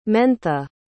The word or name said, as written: mentha